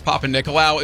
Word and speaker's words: Papanikolaou: The only reason I know how to pronounce Papanikolaou is because of Katie Nicolaou, because the last half of his name is spelled just like that.